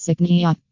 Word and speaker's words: Cycnia: It is called the unexpected Cycnia, pronounced Cycnia, moth.